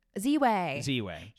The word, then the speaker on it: Ziwe: Ziwe. – Ziwe.